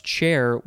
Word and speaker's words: char: Oh, it's char. It's charwoman because the word char. It's actually pronounced chair with an e.